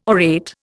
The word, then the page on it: orate: Indian